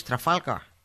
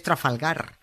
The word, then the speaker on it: Trafalgar: Que no es Trafalgar, que es Trafalgar. Que una cosa es que los guiris no sepan decirlo, que en su derecho están y no pasa nada, pero otra muy distinta, que un español snob lo pronuncie con acento inglés comiéndose todas las R's.